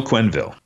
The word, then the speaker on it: Quenneville: So I'll just say, how do you pronounce Joel's last name? – Joel Quenneville. – Quenneville. I would have thrown an extra syllable in there.